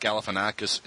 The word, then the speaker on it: Galifianakis: Galifianakis. Is that how you pronounce his name? Galifianakis? – Yep.